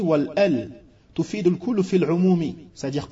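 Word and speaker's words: El (ال): Al-Mu'allif rahimahullah ta'ala nous fait part que El, c'est-à-dire Alif et le Lam, on dit El.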